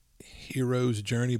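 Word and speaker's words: Hiero's Journey: Mine is it's like Hero's Journey, but it's spelled like H-I-E-R-O, by Sterling Lanier.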